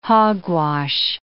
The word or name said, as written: hogwash